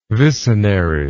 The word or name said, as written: vicenary